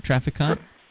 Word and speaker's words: Traficant: It's spelled T-R-A-F-I-C-A-N-T. – That's Trafficante or Trafficant? – Trafficant. James Trafficant, Jr. Trafficante.